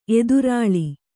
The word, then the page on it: ಎದುರುಲಿ: kannada